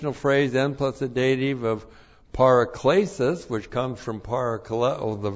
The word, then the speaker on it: paraklesis: Once again, n plus the dative is by means of, dative of means, paraklesis. This is the noun from the verb parakaleo. That's P-A-R-A-K-L, long E-S-I-S, paraklesis. Paraklesis,